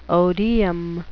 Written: odeum

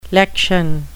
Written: lection

